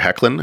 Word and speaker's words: Hoechlin: Um, but I did like uh, the Tyler Hoechlin, I think is how you say his name, his take on Superman in the Supergirl series.